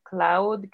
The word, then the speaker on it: Clowd: Y el de mis ilustraciones es Gemini con doble I, Cloud, que se escribe C-L-O-W-D.